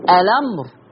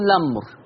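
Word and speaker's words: الأمر: لما نبدأ بيها اقصد لما نبدأ بيها يا انطق همزة الوصل اقول الامر، او اشيل همزة الوصل وابدأ باللام اقول لامر.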